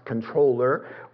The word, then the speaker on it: comptroller: He's the comptroller, pronounced controller, or he's the secretary of the treasury for the royal economy, the business, the financial affairs of the government and the nation.